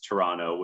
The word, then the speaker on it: Toronto: To say, so for our non-Canadian listeners who may not be aware of this, the Canadian accent, the Toronto accent is to not pronounce the second T. There can be some disputes. Some would say that you pronounce Toronto with a CH at the start, like Toronto.